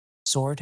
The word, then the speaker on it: sword: How do you say the word SWORD? – sword? – Yes, that is how I say it. That is how Richard says it. That is how everyone I know says it.